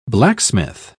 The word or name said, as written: blacksmith